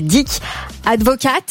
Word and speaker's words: Dick Advocaat: Dick Advocaat.